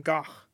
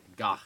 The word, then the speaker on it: Gogh: Van Gogh, actually. – Really? – Yes, because he's Dutch, so. – I don't know what the difference is. – You pronounce the G. – Gogh.